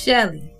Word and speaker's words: Shilie: Her her name is pronounced Shelly. Shelly, but it's spelled like Shilly. Shilly.